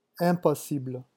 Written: impossible

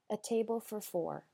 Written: a table for four

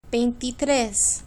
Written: veintitres

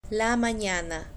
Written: la manana

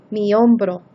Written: mi hombro

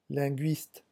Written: linguiste

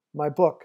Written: my book

